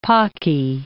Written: pocky

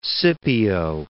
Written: Scipio

